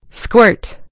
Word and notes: squirt: American English

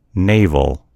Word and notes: navel: American English

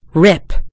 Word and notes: rip: American English

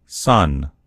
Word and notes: son: American English

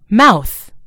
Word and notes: mouth: British English

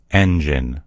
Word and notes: engine: American English

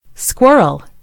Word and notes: squirrel: American English

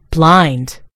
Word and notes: blind: British English

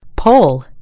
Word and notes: pole: American English

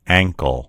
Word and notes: ankle: American English

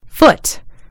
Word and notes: foot: British English

